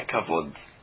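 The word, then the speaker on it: Kavod (כבוד): Se você escreve Kavod com Vav, a única forma de se ler essa palavra é Kavod.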